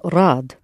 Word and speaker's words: рад: The word for glad or happy is rad. Just three letters there. Rad.